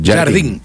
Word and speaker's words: Jardim: Ah, ya dije, Jardel. – Sí, Jardim.